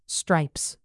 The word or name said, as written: stripes